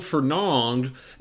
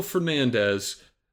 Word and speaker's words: Fernandes: And they don't pronounce his name right in FIFA. They call him Bruno Fernand. So I'm going with Bruno Fernandes.